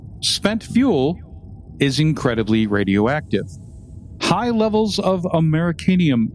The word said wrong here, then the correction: americium: How do you pronounce that? – Americium.